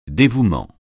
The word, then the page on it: dévouement: francais